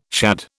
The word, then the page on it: shad: British English